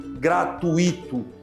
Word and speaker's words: gratuito: A gente escuta bastante as pessoas falando gratuito, com ênfase no i, como se fosse um hiato, como se fosse acentuado. Gratuito. Esse vocábulo não tem acento, pessoal. Cuidado, o lance é gratuito, gratuito. Fala a segunda sílaba junto, gratuito, gratuito. Nunca gratuito. Isso configura um erro de fonética, de som.